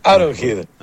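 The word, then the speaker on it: Arrowhead: Aaron Head.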